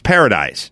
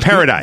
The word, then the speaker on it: Paradise: my wife's maiden name is Paradiso, but it's Paradise. – Right. – Mm-hmm. So if you come here, you just say Paradise.